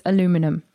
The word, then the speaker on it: aluminum: May I just refer you to aluminium, which I am absolutely convinced is how you say that word, aluminium. Now, if you're a listener in the USA, you're gonna turn your head and say what? It's aluminum. It is obviously not aluminium.